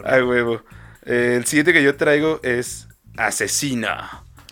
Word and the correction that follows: Aczino: Asesino. – Ajá, que se escribe A-C. Z-I-N-O.